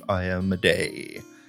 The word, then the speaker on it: Iomedae: Yeah, there's been much discussion on the pronunciation, but I believe Iomedae is correct.